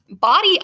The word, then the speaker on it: Bodie: So there's Body Island, pronouncing it just as it looks like, B-O-D-I-E, because they changed the spelling from B-O-D-Y over time from the original founders.